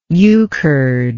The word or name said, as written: euchred